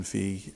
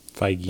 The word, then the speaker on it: Feige: these guys know what they're doing with Kevin Feige and Feige. – The boys at Feige at the MCU. Feige. I always say that wrong.